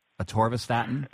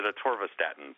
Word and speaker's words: atorvastatin: Atorvastatin? – I pronounce it atorvastatin, but atorvastatin.